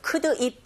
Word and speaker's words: could: So could it be? 근데 이제 이걸 우리말처럼 발음하는 것이 아니라 could의 d 사운드가 지금 앞에 보이는 l 사운드는 들리지 않는 묵음이거든요.